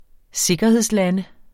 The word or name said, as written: sikkerhedslande